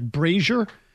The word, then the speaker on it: Brazier: Yeah, I think it's actually pronounced, I've learned this after the fact, Brazier, but I like Brazier.